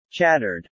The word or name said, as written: chattered